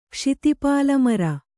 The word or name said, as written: ಕ್ಷಿತಿಪಾಲಮರ